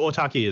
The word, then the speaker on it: autarky: Are we on to uh, anarchy? An- NRC? How did you say it? It's not anarchy. – So uh, anarchy. – Yeah. um, so autarky is a is an ancient Greek word.